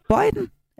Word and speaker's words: Bøjden: Søren er 53 år og fra Bøjden. Er det rigtigt udtalt? Nær Forborg? – Ja, det er rigtigt.